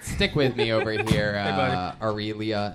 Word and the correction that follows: Aurelio: Am I saying that right? – It's actually Aurelio. – Aurelio.